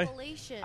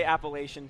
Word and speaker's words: Appalachian: Appalachian. – I say Appalachian too, but I wanted to say Appalachian because I knew she would say Appalachian.